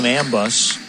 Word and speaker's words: Ambos: A-M-B-O-S, but he pronounces his name Ambus.